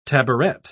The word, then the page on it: tabouret: British English